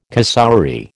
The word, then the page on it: cassowary: American English